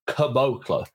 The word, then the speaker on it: Caboclo: Obviously, they've acquired the Celtics have acquired Noah Vonleh and Bruno Caboclo, and I went on to Basketball Reference to ensure that I pronounced that name correctly. K U H emphasis on the Bo. and then Klo, Caboclo.